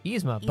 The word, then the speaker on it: Yzma: Did I say that correctly? Yzma? – Yzma.